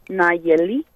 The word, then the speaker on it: Nallely: The double L is that sound, so it's Nayeli.